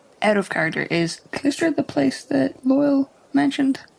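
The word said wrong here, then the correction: Calistria: Sorry, I think it's actually Callistria. I think I mispronounced that.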